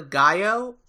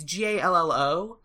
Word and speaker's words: Gallo: Uh, this is a a woman, and I'm gonna pronounce her last name wrong. I think it's Carla Gallo. It's G A L L O, but it might be Carla Gallo.